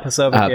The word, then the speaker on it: Posobiec: And she did not like that Jack Posobiec. Is that how you say his name? – Yeah, Posobiec.